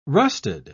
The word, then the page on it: rusted: British English